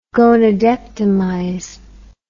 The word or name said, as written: gonadectomize